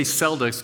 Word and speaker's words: Celtic: And we say Celtics because it's the Boston Celtics, but actually the true pronunciation is Celtic.